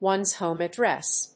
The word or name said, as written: one's home address